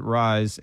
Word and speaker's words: Raiz: All right, today we are going to a restaurant with a name that is very easy to pronounce, but when you look at it, it may be a bit confusing. And it is called? – Rise Eatery. – Duh, R-A-I-Z. How else would you pronounce it? – You could do Rise, but we're going to call it Rise, and it's in the Promenade area.